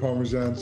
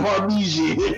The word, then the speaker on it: Parmesan: He saw frosted flakes and was like, I mean, it's kind of like Parmesan cheese. – What do you mean? Yo, I just got dizzy, man. He's like, there's nothing Parmesan about it. Yeah, I said it wrong. Parmesan.